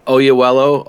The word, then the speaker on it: Oyelowo: Oyelowo.